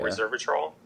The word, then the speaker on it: resveratrol: It's a resveratrol. Is that how you pronounce it? – Yeah, resveratrol.